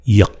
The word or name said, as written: Yik